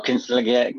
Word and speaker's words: Consigliere: How do I pronounce this? Consigliere? – Oh, consigliere. Okay, you don't pronounce it. Consigliere.